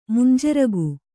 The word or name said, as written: ಮಂಜರಿಗೆ